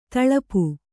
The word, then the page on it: ಥಳಪು: Kannada